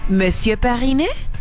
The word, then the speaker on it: Monsieur Periné: ¿Lo pronuncio bien? Monsieur Periné. ¿Está bien? Perfecto, me parece muy bien.